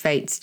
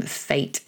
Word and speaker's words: fête: Tapio is one of our North American listeners and tells us that in the US, it's pronounced fête. – Mm. Oh, there you go. – But in English, we say fête.